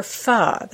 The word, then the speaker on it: pho: A couple years later, ahead of his time, he opened a very simple location on York Road for pho, that's spelled P-H-O, the Vietnamese soup.